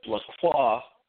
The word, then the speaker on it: LaCroix: which is spelled the same way as my last name, LaCroix, LaCroix, but I pronounce my name LaCroix, and they pronounce it Le Crux is the name of the French newspaper.